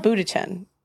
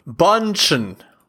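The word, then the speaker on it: Bündchen: Giselle Bundchen. I think I said that wrong. – Bundchen.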